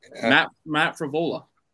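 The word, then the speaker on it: Frevola: But how do you pronounce his name again? Devizvola? – Matt Favola.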